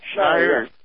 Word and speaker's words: Schneier: Is that how you pronounce his name, Bruce Schneier? – Schneier.